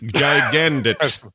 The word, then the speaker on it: Gigandet: Gigandet. Is that right? Cam Gigandet? Do I have it right? – Yeah, that's nailed it. Gigandet. – Gigandet.